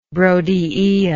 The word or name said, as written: brodiaea